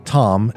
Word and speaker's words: Thom: Tom is a nickname, and I spell it T-H-O-M for Tom, right?